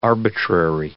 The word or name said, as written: arbitrary